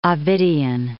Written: Ovidian

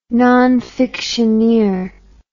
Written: nonfictioneer

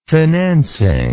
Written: financing

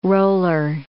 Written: roller

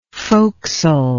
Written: focsle

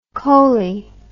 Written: coley

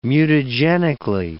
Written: mutagenically

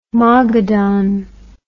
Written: Mogadon

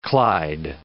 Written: clyde

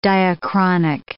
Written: diachronic

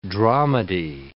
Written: dramedy

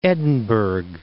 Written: Edinburg

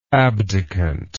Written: abdicant

